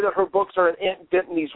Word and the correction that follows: Dimity: The name of the book is actually Aunt Dibny, or Dimity, probably. Dimity, you know I can't pronounce stuff. Aunt Dimity and the Village Witch.